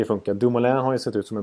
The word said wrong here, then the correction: Dumoulin: en bra back eh, även i Nej, Dumoulin ska jag säga. Jag har ett obegripligt uttal på Dum Dumoulin. Dumoulin, det kan till och med jag faktiskt.